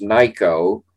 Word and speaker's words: Nikko: So this guy whose name's Nyko rhymes with psycho, they point out, even though it's spelled N-I-K-K-O.